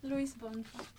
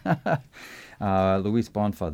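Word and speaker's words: Luiz Bonfá: Luis Bonfa, the name of the composer, right? Did I say that right? – Yeah.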